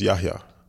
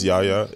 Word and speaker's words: Yahya: But if you ask how my name is pronounced, it's Yahya. – Yahya. – Yahya. Yeah. So the H is not silent. Everyone says Yahya.